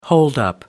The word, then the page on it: holdup: British English